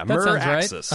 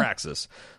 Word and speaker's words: Meraxes: How would you pronounce that? Meraxes? – Yeah, Meraxes. – Right. Uh-huh. – Meraxes.